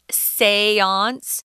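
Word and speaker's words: séance: It's kind of a weird word. It's also French. That's how it's pronounced seance.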